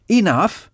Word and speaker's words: enough: Enough s'écrit E-N-O-U-G-H. Enough. Enough.